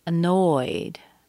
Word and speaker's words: annoyed: We have annoyed. Annoyed. You hear the stress on the second syllable. The first syllable is a schwa. It's very weak.